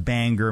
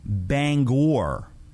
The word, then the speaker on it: Bangor: It's not pronounced Bangor, Maine. Bangor, Maine is wrong. Bangor. Bangor.